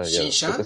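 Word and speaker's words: Shin-chan: Por ejemplo, eh, Shinshai o Shinshai, ¿cómo se pronuncia? – Shinshan. sabes más.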